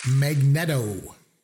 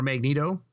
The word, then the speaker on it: Magneto: Or Magneto. That'd be the X-Men. – Magneto. Is that his name? I don't know how to pronounce it. I don't know. – I've heard it pronounced both ways.